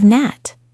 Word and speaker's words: gnat: Think of gnat. It has a silent G.